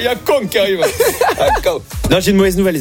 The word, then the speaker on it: Akon: Oui, il faut le prononcer aussi à la française, je veux dire. Oui, vit dans un pays de droit et de valeur. C'est Akon que ça se prononce et c'est ça, Akon.